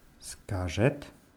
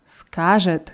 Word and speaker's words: Скажет: Und die dritte Form für er oder sie war Скажет. – Скажет. Die Betonung ist auf dem A.